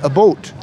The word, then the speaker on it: about: For example, about, they say a boat.